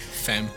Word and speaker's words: fem: Fem. I mean, five. – Fem. – That's how you say it in Swedish.